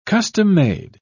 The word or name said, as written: custom-made